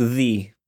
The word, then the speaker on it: ye: It's pronounced the, not ye.